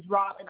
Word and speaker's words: Robyn: And my first name is Robin, R-O-B-Y-N. – Okay, and that might be why. – Okay. – R-O-B-Y-N-F-I-N-C-H.